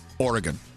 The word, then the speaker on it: Oregon: By the way, and you say it correctly, Oregon. – Yes, yeah. I yell at everybody. I'm like, that's not how you say it.